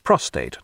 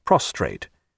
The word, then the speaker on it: prostate: its location vague and its name often mispronounced. It's prostate, not prostrate.